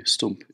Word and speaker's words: stoemp: And I think of this dish you've just described, stoomp. Am I saying that right? – Yeah, S-T-O-E-M-P, stoomp, yes.